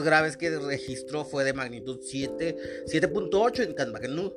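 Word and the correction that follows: Katmandú: no lo puedo pronunciar. Kathmandú. Ahí está.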